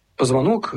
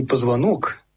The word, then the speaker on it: позвонок: Позвонок, или, наверное, даже правильней говорить не «позвонок», а «позвонок» с ударением на вторую «о», потому что в старорусском языке еще «позвонок» назывался «подзвонок» и обозначал колокольчик, подвешиваемый на шею скоту.